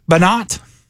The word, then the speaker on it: Banat: Banat. B-A-N-A-T. – That's Banat, the way you pronounce his name.